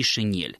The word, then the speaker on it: шинель: Все-таки интеллигентные люди знают, что и в том, и в другом случае произносится мягкий «н». То есть правильно мы говорим «фанера» и «шинель».